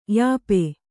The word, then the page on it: ಯೂಪ: yūpa